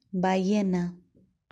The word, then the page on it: ballena: Spanish